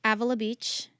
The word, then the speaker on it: Avila Beach: I love Avila Beach.